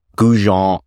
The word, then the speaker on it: Gougeon: Kevin, you've got a background in education with Georgian College and with, I'm going to totally butcher this name, Gougeon Insurance.